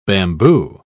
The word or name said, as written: bamboo